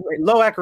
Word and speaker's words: Loak: And it's Loak rhymes with snack.